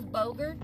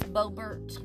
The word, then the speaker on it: Boebert: I think that's how you say her name. I thought it was Bogert, but it's Boebert, I think.